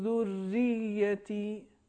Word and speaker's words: ذریتی: ایک تو پہلی آیت, یعنی آیت نمبر 40 میں لفظ ذریتی, اس میں دو تشدید ہیں. ایک تشدید حرف رے پر ہے اور ایک تشدید حرف یہ پر ہے. دونوں کو مشدد پڑھنا چاہیے, لہٰذا صحیح تلفظ یہ ہے. رب اجعلنی مقیم الصلاۃ ومن ذریتی, اگر ہم کہیں گے ذریتی, یہ تشدید اس کا ادا نہیں ہو پایا.